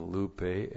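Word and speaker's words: Lupe (λύπη): This is the definite article, H, long E, and then Lupe, L-U-P, long E. This is the genitive singular of the definite article, and the genitive is T-O-U.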